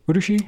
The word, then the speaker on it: Urushi: These are ebonite pens with Urushi lacquer. Did I say that right? Urushi? – Yes.